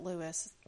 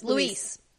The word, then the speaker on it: Luis: But what if, okay, what if they introduced Stony Birch in order to have their second string characters like, it's not Lewis, Louis. – Luis. – I knew I was going to say it wrong.